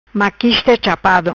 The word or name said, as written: Chapado Maquista